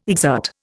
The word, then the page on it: exert: Indian